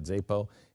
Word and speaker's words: Xapo: I have Zapo or XAPO. How do you say that? Zapo.